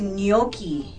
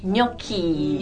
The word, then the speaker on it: gnocchi: It's gnocchi. – Gnocchi. – Gnocchi. So the G is silent. – 对，这个大家看拼法呢，不要被它的这个发音吓到啊。它的拼法是 G-N-O-C-C-H-I.